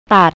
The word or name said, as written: tat